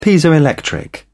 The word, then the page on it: piezoelectric: British English